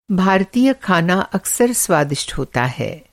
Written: स्वादिष्ट

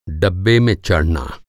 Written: डब्बा

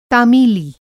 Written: तामीली

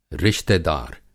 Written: रिश्तेदार

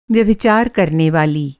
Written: व्यभिचार

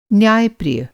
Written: न्याय-प्रिय

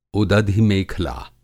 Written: उदधि मेखला

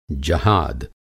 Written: जहाद

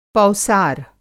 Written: पौसार

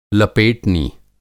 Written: लपेटनी

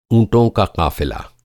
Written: क़ाफ़िला

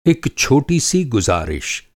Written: गुज़ारिश

